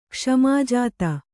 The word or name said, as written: ಕ್ಷ್ಮಾಜಾತ